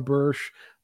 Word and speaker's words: Bursch: Is it Bursch? – Yes, it's Bursch. – Yes. The author, John Bursch, the title of the book, Loving God's Children.